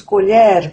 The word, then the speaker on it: colher: Quando nos referimos à colher com a qual comemos, dizemos colher com a sonoridade de acento agudo no E.